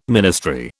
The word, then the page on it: ministry: British English